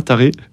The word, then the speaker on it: Taré: Et voilà, en arrivant en revanche à mon lieu de mission, à Taré, un nom, voilà, ça se prononce vraiment comme ça, Taré.